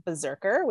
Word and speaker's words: BRZRKR: My first honorable mention is Keanu Reeves' graphic novel / comic series Berserker, which is spelled BRZRKR. So basically no vowels.